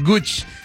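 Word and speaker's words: Gucci: Ya me dijeron por acá que no se dice Gooch, es Gooch.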